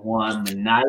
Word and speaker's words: Juan Minaya: Mr. Juan Minaya.